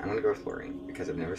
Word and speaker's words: Laureen: Okay, upon looking up a YouTube video, it is Loreen.